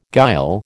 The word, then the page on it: guile: American English